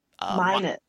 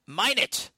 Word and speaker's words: Minot: Is it Minot. – Minot.